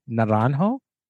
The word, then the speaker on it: Naranjo: Yeah, and just to kind of bring it to modern day, from what I understand, there's a Chilean psychiatrist named Claudio Naranjo.